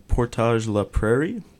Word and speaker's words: Portage-la-Prairie: But don't forget, U-Sports Championships for this curling team will be in Portage-la-Prairie.